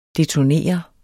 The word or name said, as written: detonere